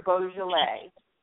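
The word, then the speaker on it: Boisjoly: And he, his name was Roger Beaujolais, and it's not spelled like the wine, but it's pronounced just like the wine.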